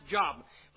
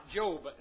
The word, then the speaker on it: Job: No, it is not pronounced Job. Pronounce it right, Job. And that is Job.